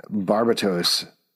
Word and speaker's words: Barbatos: Barbatos is actually how you pronounce it.